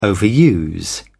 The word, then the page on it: overuse: British English